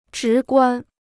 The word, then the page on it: 直观: zhí guān